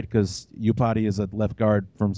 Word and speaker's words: Iupati: Is it Iupati? – Iupati, yeah. – Iupati, there you go.